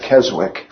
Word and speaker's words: Keswick: It's spelled K-E-S-W-I-C-K. It sounds like Keswick, but it's pronounced Keswick.